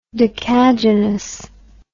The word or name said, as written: decagynous